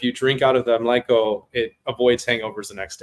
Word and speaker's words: mlíko: So the first one, do beers poured in the mlíko, is that how you say it? – Yeah. – The glass? – Mlíko. – Mlíko.